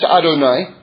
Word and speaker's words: Adonai: And it's Aleph Dalet Nun and Yud, pronounced Adonai.